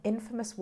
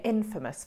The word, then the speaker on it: infamous: I'm watching a documentary on infamous war criminals. The company are infamous for not paying their taxes. – Quick pronunciation tip. Both words have the stress on syllable one, so sound very different.